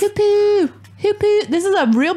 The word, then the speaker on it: Hoopoe: The River Hoopoo. That's how you say it. The Hoopoo. – Is it the Hoopoo or is it the Hoopoe? – Hoopoo.